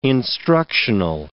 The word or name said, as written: instructional